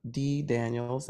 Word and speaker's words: Dee Daniels: If they go to my website, which is my name, D Daniels,